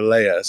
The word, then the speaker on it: Leah: I always say Leah or Leah. So I'm in the Hebrew is Leah. I guess I should just say it like that.